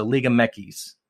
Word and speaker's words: Liga MX: Well, X is Equis. – Equis. So they call it Mekis. – That's how you pronounce Liga X in Spanish. Yeah. Equis. – But in English, like Joe Equis, Liga MX. Right? So yeah. So Liga Mekis. Mekis.